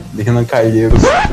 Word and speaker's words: Renan Calheiros: Terceiro lugar, do meu amigo Renan, Calhe... Renan Calheiros, não pronunciei de Curitiba.